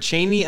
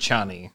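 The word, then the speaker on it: Chani: So I've heard Chaney and I've heard Chaney. – And those are the only two you should hear. A third person is wrong.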